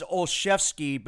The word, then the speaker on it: Olszewski: I thought it was Olszewski, but now I'm being told you don't pronounce the L and it's just Olszewski.